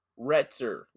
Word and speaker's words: Retzer: Retzer with an R. R-E-T-Z-E-R. Retzer. – That's a pretty cool last name. – No, it's not. It's horrible. – I like it. – I don't. Lame. Retzer.